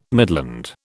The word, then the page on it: midland: British English